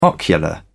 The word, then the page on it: ocular: British English